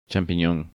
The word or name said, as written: Champinon